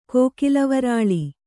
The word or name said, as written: ಕೋಕಿಲವರಾಳಿ